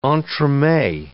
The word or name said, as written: entremets